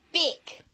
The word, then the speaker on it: Bic: Rhymes with stick. – Bic. – Bic is good.